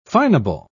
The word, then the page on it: finable: British English